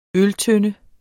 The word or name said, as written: øltønde